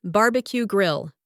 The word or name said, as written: barbecue grill